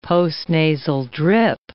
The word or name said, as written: postnasal drip